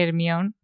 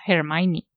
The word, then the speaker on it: Hermione: bueno, que yo me quedé loca cuando me enteré que Hermión se pronunciaba Hermione.